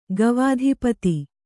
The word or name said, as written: ಗವಾಧಿಪತಿ